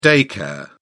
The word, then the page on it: day care: British English